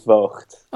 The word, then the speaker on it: Voogt: Yeah, so my name is Budi Voogt. – Okay. – Now that G is difficult. – And it's spelled B-U-D-I, and then the last name is V-O-O-G-T.